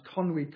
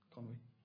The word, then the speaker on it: Conwy: A year ago, I went to Wales with my two eldest boys and we walked around Conwy Castle. Is that how you say it? Conwy.